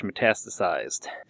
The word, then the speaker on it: metastasized: In 1978, the year Starcrash metastasized. Is that right? Was I close? I was right. – Yeah, you said it right.